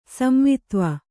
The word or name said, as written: ಸಂವಿತ್ವ